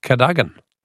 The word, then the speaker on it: Cadogan: Jane from Homebush says it's not pronounced card. It might be spelt cardigan, but it's pronounced Cadogan.